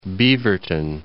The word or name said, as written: Beaverton